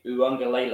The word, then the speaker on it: Uiagalelei: DJ Uiagalelei, I feel pretty confident that I said that right.